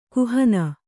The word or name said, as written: ಕುಹನ